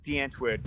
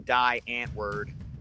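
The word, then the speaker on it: Die Antwoord: It's pronounced D'Antwood. Don't call him Die Antwood. You're wrong. – I get it. You're wrong. But phonetically, it would be Die Antwood, right? – Like, no, it's D'Antwood.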